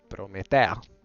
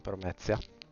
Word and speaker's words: Prometea: Il mio nome in realtà è Prometea, non Promezia. – L'accento, è l'accento che... – No, la T. – Inganna. Sì, sì. – C'hanno la Z, non... Promezia.